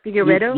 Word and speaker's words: Figueiredo: How do you say Figueiredo, right? Figueiredo? – Yeah. – Figueiredo? – You got it right.